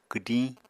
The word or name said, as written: kwdiñ